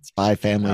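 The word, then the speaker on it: Spy × Family: Spy Family is great. Don't need to pronounce the X on that one. – What? Spy Cross Family? It's not Spy. – No, it's just Spy Family.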